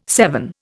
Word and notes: seven: Indian

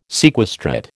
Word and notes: sequestrate: American English

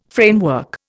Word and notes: framework: Indian

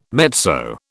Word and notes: mezzo: British English

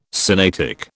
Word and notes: Sinaitic: British English

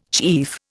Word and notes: chief: Indian

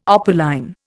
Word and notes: opaline: Indian

